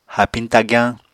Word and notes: haapiñ tagyañ: Apatani

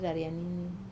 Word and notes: raria nini: wamesa

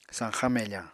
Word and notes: sañxañ mefañ: Apatani

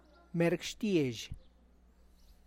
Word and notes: "merg" xtiazh: Tlacochahuaya Zapotec

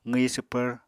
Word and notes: qwi swpvr: Apatani

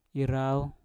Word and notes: irau: Mpur

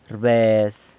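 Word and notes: rbez: San Lucas Quiaviní Zapotec

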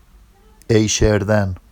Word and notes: ejʃerdan: Vafsi

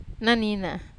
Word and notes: nina: Wamesa

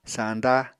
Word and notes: sañda: Apatani

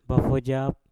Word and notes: bafojap: Mpur

